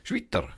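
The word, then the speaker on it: Schwyter: Also auf jeden Fall eine, eine Heilpraktikerin äh, mit Namen Schweiter. Das wird wahrscheinlich in der Schweiz auch irgendwie merkwürdig ausgesprochen, weil S-C-H-W-Y. Wie spricht man das? Schwitter? Schwitter. Schwitter. Ja, die Frau.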